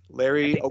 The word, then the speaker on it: Ogunjobi: Ogunjobi. That's how it's pronounced.